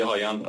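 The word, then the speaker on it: Rayan: من فکر میکنم کلید بازی دست وینگرهای برزیل، وینی جونیور و هایان، تلفظ برزیلی هایان، رایان در واقع.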